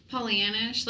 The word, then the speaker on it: Pollyannish: Like a nice little, what is it, Pollyannish? Is that how you say it? – Yeah. – Pollyannish.